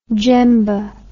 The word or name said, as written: jembe